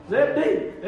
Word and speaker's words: Zabdi: And that is spelled Zabdi. Amen. I looked that up as well. And it's Z-A-B, amen, D-E-E. – Amen. – Zabdi.